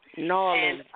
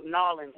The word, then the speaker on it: New Orleans: New Orleans, yay. She's from New Orleans. – Norland. – Norland, I'm sorry. I'm sorry, I didn't mean to pronounce it wrong.